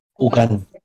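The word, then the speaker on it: Houngan: El Bokor es un hechicero, un como la contraparte de los Ugans, ¿cómo, era? – Ugan. – Ugan.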